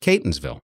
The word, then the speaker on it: Catonsville: So I guess it's pronounced Catonsville. So thanks, Kevin G.